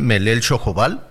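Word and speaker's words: Melel Xojobal: Por ello, hoy eh, eh, me da mucho gusto platicar con Jennifer Asa, directora de Melel Chojobal asociación civil. Antes que otra cosa, Jennifer, ¿lo dije bien? ¿Melel Chojobal? Pues muy bien.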